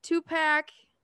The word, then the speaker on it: Tupac: Was it the Notorious B.I.G., Tupac? Oh my God, did I say that wrong? Tupac? Tupac!